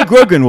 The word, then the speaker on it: Grogan: It rhymes with Joe Rogan. – It does rhyme with Joe Rogan, yes. Ray Grogan will be here next week.